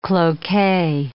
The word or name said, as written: cloque